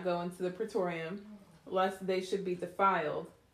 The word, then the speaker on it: praetorium: Then they led Jesus from Caiaphas to the praetorium.